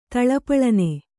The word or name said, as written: ಥಳಪಳನೆ